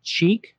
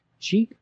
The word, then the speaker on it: Chic: It's spelled C-H-I-C, Cheek. Cheek is the name of that band.